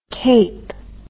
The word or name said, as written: keap